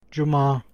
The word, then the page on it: fredag: /ˈfreːdɑːɡ/; /ˈfreːda/